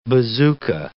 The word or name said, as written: bazooka